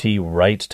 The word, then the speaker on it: Wright: And his name is spelled W-R-I-G-H-T, Wright Thompson, but he can really write. Wright can really write, okay? W-R-I-T-E.